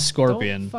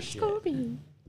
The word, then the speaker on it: Scorpion: How could you do that to Scorpion? Scorpio. He doesn't even know how to say the fucking name right. He says Scorpio.